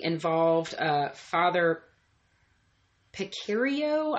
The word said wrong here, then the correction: Pecoraro: I'm probably going to butcher this guy's name. I'll have to find it exactly so I don't butcher his name, guys. But he mentioned there has been speculation. Let me see. Father Pecoraro. Sorry, guys. Father Pecoraro. – You try to pronounce that like that. – Yeah, Pecoraro. Hooked on phonics.